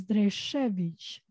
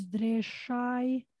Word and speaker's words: Dresevic: Well, the authentic pronunciation is Dresevic. Then there is also like the Albanian version, as I call it, which is Dreshai.